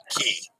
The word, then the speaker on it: Cay: C-A-Y is pronounced key properly. Holland America does say Half Moon Key. So, Key always. Yep, I know, Dennis. I was just not thinking when I said that earlier. Proper pronunciation is always key.